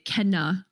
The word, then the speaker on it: Kena: Celta versus Kena is pronounced Kena. If you go back to the first trailer, a character says her name. – No, the state of play said it clearly said Kena.